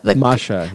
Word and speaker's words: Masha: And watching over them is Masha. Am I saying that right? So it's the Masha. Yeah.